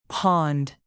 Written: pond

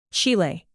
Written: chile

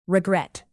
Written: regret

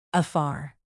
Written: afar

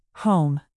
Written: home

